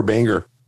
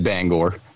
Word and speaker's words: Bangor: Do they say Bangor or Banger? – They say Bangor. They actually said it right. – Because you would think one of them would call it Banger.